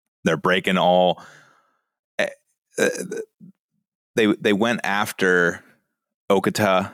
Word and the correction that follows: Okta: How, how do you say that? – Uh, Okta. Yeah, I always mess that up.